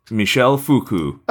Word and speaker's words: Michel Foucault: Michel Foucault.